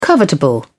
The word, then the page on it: covetable: British English